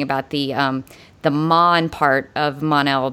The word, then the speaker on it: Mon-El: Based on the origin of the name Mon-El, I would assume that the Mon was originally intended to be pronounced the same way as the first and penultimate. I see what you're doing there, Lynn. The penultimate syllable of the word Monday.